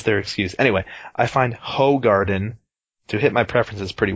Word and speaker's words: Hoegaarden: I find Hoegaarden. Did you know that they tell you how to pronounce that on their bottle? Yeah, they say it's Hoegaarden. They're like, it's Hoegaarden, you motherfucker. Stop saying Hoegaarden.